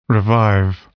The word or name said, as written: revive